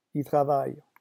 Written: il travaille

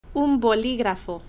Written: un boligrafo

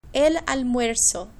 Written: el almuerzo

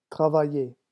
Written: travailler

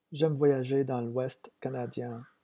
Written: j'aime voyager

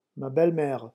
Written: ma belle mere

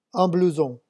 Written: un blouson

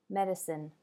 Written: medicine

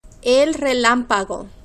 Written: el relampago

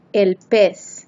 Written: el pez

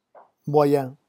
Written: moyen

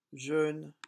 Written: jeune

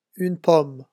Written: une pomme

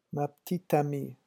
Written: ma petite amie

